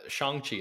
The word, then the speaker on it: Shang-Chi: So I think it's just like like that's how you read it, you know, in a English, a Western, yeah, from a Western perspective. But Shang-Chi is evidently the correct pronunciation.